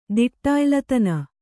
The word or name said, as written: ದಿಟ್ಟಾಯ್ಲತನ